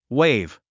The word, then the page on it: wave: wéiv; ウェイブ